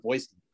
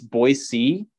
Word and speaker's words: Boise: And there's actually one of Boise and from Boise State, which, by the way, big gripe. It's Boise, not Boise. If you're saying it with a Z, you're not from here.